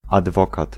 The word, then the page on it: adwokat: [ad̪ˈvɔkat̪]; /adˈvɔkat/